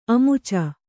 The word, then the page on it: ಅಮುಚ: amuca